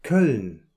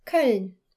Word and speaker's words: Köln: In German, you refer to the city of Cologne as... – Köln. – Köln.